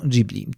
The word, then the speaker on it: Ghibli: Jedni mówią Ghibli, inni mówią Ghibli. W takim razie, jaka jest prawidłowa wymowa? Japończycy mówią Ghibli, to jest dość jednoznaczne,